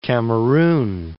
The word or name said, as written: Cameroun